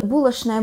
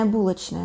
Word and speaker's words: булочная: Так, например, произошло изменение нормы звукосочетания в таких словах, как булочная, булочная, да, еще некоторое время назад недопустимо было произносить булочная, нужно было говорить только булочная, но сейчас это изменилось, и сейчас можно уже говорить и так, и так, а скоро слово булочная в произношении шин уже будет устаревшим, да, и уже эта норма тоже движется.